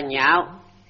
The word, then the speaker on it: nhạo: Chỗ này đọc là nhạo, tức là anh ưa thích.